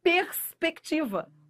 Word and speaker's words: perspectiva: Elas comem o R. Só que não, ó. Fale. Fale, fale, pronuncie para você ver, ó, perspectiva. O R é pronunciado, não há dígrafo.